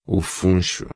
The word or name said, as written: funcho